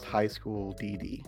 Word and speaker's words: High School DxD: But like the X in this regards, when it comes to a Japanese title, it represents like an ampersand that you don't pronounce. So really it would be like High School D and D, but it's just said High School DD.